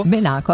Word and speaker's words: Benaco: C'è questo comune, che sbaglierò sicuro l'accento, Torri del Benaco. – Benaco, a me sembra che si dica così, però vedrai che sbaglieremo.